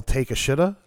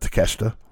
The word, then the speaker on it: Takeshita: Because as soon as I looked at it, I did the whole take a shita. Yeah. Right? Because that's how his name is spelled. But Takeshita. Yeah.